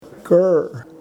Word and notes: krr: Yurok

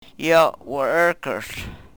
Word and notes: yo wr-rrkrhl: Yurok

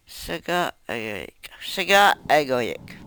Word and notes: segaagoyek: Yurok

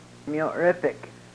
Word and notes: myorepek: Yurok

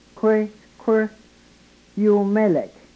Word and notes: kwrykwryumelek: yurok

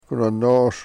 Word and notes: ku ne-nos: Yurok

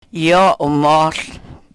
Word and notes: yo u-mohl: Yurok

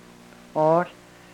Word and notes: oohl: Yurok

